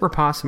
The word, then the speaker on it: Rapace: So they both said, is it Rapace? Am I saying that correctly? – Yes.